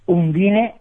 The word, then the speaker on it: Undine: ¿Cómo pronunciamos estas películas? ¿Cómo se pronuncian correctamente las que vas a analizar hoy? – Undine. – ¿Undine, así nomás? – Así nomás.